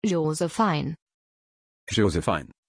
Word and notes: jozefine: German

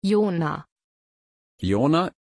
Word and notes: Yonah: German

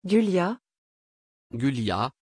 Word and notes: gulia: French

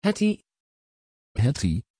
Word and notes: hetty: Dutch

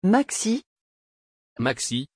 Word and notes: Maxie: French

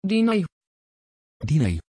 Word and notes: Dinah: Dutch

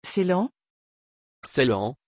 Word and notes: ceylan: French

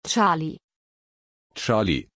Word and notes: charlie: German

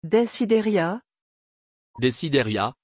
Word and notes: desideria: French

